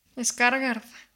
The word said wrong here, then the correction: Skarsgård: ¿Cómo se pronuncia el apellido? – Skarsgard. Skars, Skarsgard.